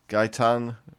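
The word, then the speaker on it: Gaëtan: Gaetan. – Yeah, Quebecois, Canadian flight attendant. – So that's Gaetan. G-U-Y-T-A-I-N. – It says Guy, like G-A-I. It has the pronunciation.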